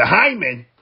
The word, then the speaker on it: Heinlein: Elon Musk received the Heinlein.